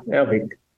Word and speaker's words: Avik: Yeah, Avik.